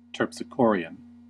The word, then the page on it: terpsichorean: American English